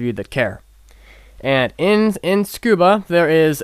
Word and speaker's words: Tsukuba: It's spelled T-S-U-K-U-B-A, but when you say it, it sounds like scuba diving, because the Japanese people speak really quickly and they tend to blend things together.